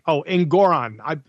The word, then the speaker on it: Engoron: This moron judge, Engoron, it just rhymes with moron, doesn't it?